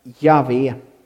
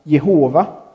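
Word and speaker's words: Jahve: Men det allra troligaste uttalet på Guds namn är Javé. Men ibland så kan man också höra Jehova.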